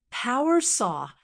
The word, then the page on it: power saw: American English